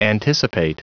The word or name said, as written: anticipate